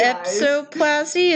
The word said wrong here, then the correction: episioplasty: is that how you say it? – No, episioplasty.